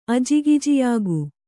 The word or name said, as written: ಅಜಿಗಿಜಿಯಾಗು